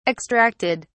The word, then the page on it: extracted: British English